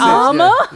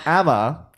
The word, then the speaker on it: Amma: I think because you want to say it like Mama. So you're like Amma. – Yeah. Amma.